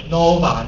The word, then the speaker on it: Nova: Nova 那一栋，发音要 Nova 那一栋，还有教室啊。